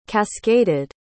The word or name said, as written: cascaded